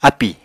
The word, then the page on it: ápì: Apatani